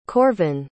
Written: korvin

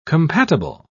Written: compatible

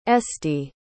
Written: estie

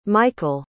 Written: mychal

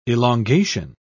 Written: elongation

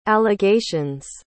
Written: allegations

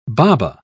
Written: baba

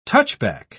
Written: touchback

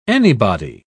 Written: anybody

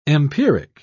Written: empiric

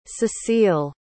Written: cecile

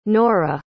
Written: noura

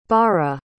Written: bara

